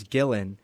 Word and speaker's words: Gillen: But my full name is Gillen. And I get, when people read that, I get Gillen, Gillian, and some people just Guillaume if they think I'm French.